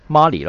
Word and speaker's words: Marley: 英文是 When Mary，不是 Mary 那个字怎么读啊？ – Marley 咯。